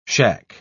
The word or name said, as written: shack